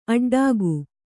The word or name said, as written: ಅಡ್ಡಿಗೆ